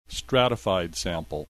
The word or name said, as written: stratified sample